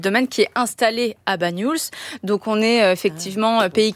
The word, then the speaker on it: Banyuls: Je trouve qu'il a presque un nez de Banyuls. Comment on le prononce ? – Banyuls. Oui, ouais. C'est ça.